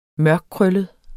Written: mørkkrøllet

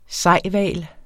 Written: sejhval

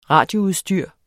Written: RADIOUDSTYR

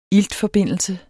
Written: iltforbindelse

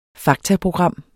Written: faktaprogram

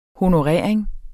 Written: honorering

